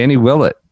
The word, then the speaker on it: Willett: But yeah, BMW, Danny Willett brings it home at Wentworth. – Got that pronunciation. Nailed it.